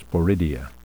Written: sporidia

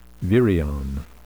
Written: virion

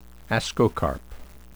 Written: ascocarp

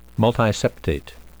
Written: multiseptate

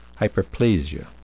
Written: hyperplasia

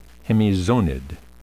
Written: hemizonid

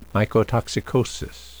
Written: mycotoxicosis

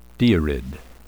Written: deirid

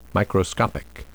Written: microscopic

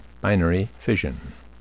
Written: binary fission

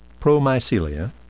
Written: promycelia

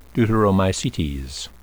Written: deuteromycetes

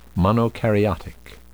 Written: monokaryotic

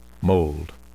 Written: mold

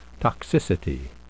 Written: toxicity

